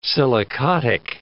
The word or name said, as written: silicotic